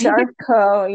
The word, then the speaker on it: Charcot: And then the Charcot, did I say that right? Charcot Marie. Sure. It's Charcot, yeah.